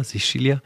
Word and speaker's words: Sicilia: Er hat nämlich, mit ihm habe ich letztens über sein Buch Terra Sicilia gesprochen, so spricht man es, oder? Sicilia? – Ja, ja.